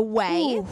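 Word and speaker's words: Ouai: All right, my current fixation, have you guys heard of the rose oil from Whey? – No. – Okay, so Whey is Jen Atkins' hair product line.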